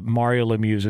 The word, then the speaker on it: Mario Lemieux: We were talking about the Mario Lemieux's involvement with ownership of the Penguins yesterday.